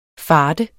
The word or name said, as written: fart